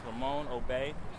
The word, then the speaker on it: Ramon: My name is Ramon Obey. First name is spelled R-A-M-O-N. Last name is spelled O-B-E-Y.